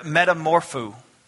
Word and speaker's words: metamorpho: Now this word transformed is this word metamorpho. And I didn't pronounce that wrong. It's a Greek word, metamorpho. What does that sound like? Metamorphosis.